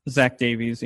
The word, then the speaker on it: Davies: We do have Cueto on the mound against, I think his name is Davis or Davies. Is that how you pronounce his last name? – Zach Davies, yeah.